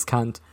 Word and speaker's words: Kant: It's like in German, it's just pronounced as Kant. – It's Kant. Kant. Immanuel Kant.